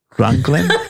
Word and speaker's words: Rognlien: Ronglien? – We say like rung of a ladder and Ronglien.